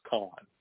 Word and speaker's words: Khan: Kahn. Now, it's spelled K-H-A-N, but it sounds like the C-O-N, which I think she really is.